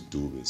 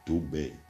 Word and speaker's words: Dube: Even my surname is Dube, so it's like Dube. Like what? – Dube.